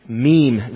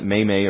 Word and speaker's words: meme: I used to pronounce it may-may or mame, and somebody corrected me.